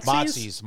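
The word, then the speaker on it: Mozzi's: Motsy's.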